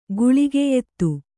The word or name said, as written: ಗುಳಿಗೆಯೆತ್ತು